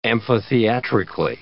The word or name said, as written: amphitheatrically